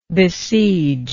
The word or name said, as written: besiegingly